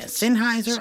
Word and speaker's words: Sennheiser: Sennheiser? Yeah, Sennheiser.